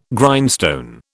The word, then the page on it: Grindstone: British English